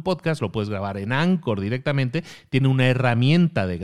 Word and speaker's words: Anchor: Bueno, pues tiene también herramientas y una de ellas que la compró hace unos años se llama Anchor se pronuncia Anchor, se escribe Anchor, es un ancla, digamos.